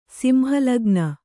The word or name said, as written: ಸಿಂಹಲಗ್ನ